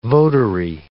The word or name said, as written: votary